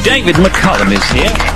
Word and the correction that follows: McCallum: It's David McCallum. Get the name right. – David McCallum is here. I always have called him David McCullum, you know, but he's David McCallum. – David McCallum, quite right. – Yeah, it's yeah. Like California.